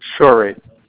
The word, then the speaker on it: Shorey: Shorey. – How do you spell that? – S-H-O-R-E-Y.